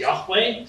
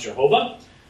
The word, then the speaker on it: Yahweh: In the Old Testament, the divine name used this one true God is Yahweh. Sometimes you hear it pronounced Jehovah.